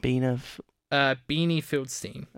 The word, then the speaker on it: Beanie Feldstein: Beanie Feldstein.